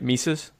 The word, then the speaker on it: Mises: I'm saying that right, right? Mises? – Yeah.